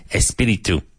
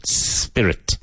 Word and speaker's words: spirit: Espiritu. Espiritu. Spirit. Careful of the pronunciation. Spirit. Not espirit. Spirit.